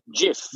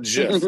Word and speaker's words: GIF: GIF. – Not GIF. It's not GIF. Let him know that because it's not it's graphic interface, not graphic.